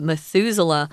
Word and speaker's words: Methuselah: Methuselah. It's kind of weird, but that's how you say it, Methuselah.